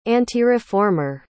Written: antireformer

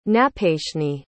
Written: napayshni

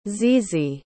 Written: zizi

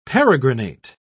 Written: peregrinate

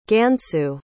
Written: gansu

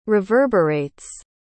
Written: reverberates

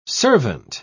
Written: servant

